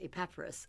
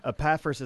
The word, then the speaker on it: Epaphras: But the way it's divided in syllables, it didn't sound like it would be Epaphras. But that's how he said it. – Yeah. Epaphras is the most common mm-hmm. pronunciation of it.